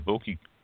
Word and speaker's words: bocce: Bocce ball, isn't it? Bocce? – Bocce? Okay, Bocce? Okay, I know I always pronounce it wrong, and that's Bocce.